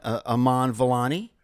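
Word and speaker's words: Iman Vellani: Kamala Khan, Aman Vellani.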